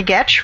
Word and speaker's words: Getch: My name is Sally Getch, rhymes with sketch.